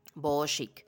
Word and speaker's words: boho chic: ustedes colocan estilo decorativo boho chic. Se escribe B-O-H-O y chic, bueno, sería C-H-I-C, boho chic.